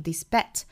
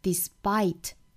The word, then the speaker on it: despite: despite 这个单词我们要注意不要发成了 despite，要注意后面这个是双元音 i 的发音了 despite，